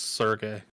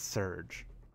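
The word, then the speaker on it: Serge: Also, I contend that his name is pronounced Sergey. – No, it's Surge. – But it's spelled like Surge.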